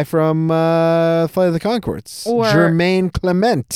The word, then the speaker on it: Jermaine: There is no R. It's Jermaine.